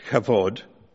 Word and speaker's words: Kavod: And in one of the chapters, which he titled Kavod, that is my bad pronunciation of a Hebrew word, spelt K-A-V-O-D, Kavod.